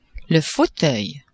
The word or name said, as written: fauteuil